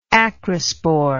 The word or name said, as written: acrosporous